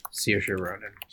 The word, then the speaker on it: Saoirse Ronan: I'm going to go with Saoirse Ronan. Is that how you pronounce it? – Saoirse Ronan.